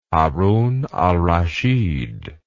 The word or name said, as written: harun al rashid